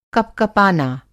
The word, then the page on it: कपकपाना: کپکپانا